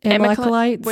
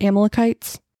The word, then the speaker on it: Amalekites: Amalekites? – Amalekites? – Wait, where? – Verse 29, the Amalekites, I think that's it, Amalekites.